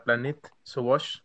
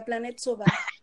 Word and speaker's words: Planète Sauvage: En francés, es de la Planète Sauvage. – La Planète Sauvage.